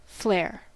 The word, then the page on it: flair: /flɛə/; /flɛɚ/; /flɛə(r)/